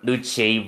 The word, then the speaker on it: Lucci: Okay, I gotta remember. Lucci rhymes. – It rhymes with Smoochie.